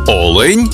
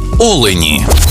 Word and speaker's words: олень: в однині, і в множині наголос падає на перший склад. Олень та олені.